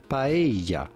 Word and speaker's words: paella: So how do you go about pronouncing this with the typical Spanish pronunciation? It would be paella.